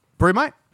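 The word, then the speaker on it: BrüMate: Yeah, brew, but it's spelt brew with mate. Like a U with a double... – Brew mate? – But with a double dot over the U. – Brewmate. – Yeah, brewmate. Yeah, one of those.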